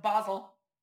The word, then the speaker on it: Basel: Did I say that right? Basel. – Nope, Basel.